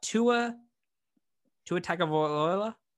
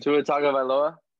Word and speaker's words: Tua Tagovailoa: We got Tua Tagovailoa. I butchered that so badly. – Tua Tagovailoa.